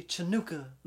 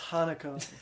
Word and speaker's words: Hanukkah: And he's like, have a happy Chinooka. He did not know that it was just pronounced Hanukkah. – Chinooka. – It's spelled Chinooka. C-H, but you don't pronounce it Chinooka.